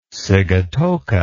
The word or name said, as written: sigatoka